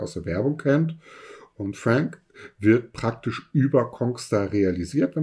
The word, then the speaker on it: Fraenk: F-R-A-E-N-K, ist richtig ausgesprochen, Frank,